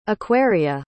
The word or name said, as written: aquaria